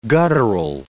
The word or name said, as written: guttural